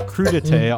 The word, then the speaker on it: crudité: You could either have your baby carrots and broccoli on your lazy Susan, or you could have your crudité on your épergne.